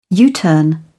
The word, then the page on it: U-turn: British English